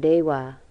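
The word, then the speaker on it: deva: And when there's a V in a word, it's pronounced like a W, like dewa or awera.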